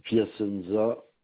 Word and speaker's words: Piacenza: C'est Piacenza Frédéric. – Ça s'écrit comment, s'il vous plaît? – P-I-A-C-E-N-Z-A.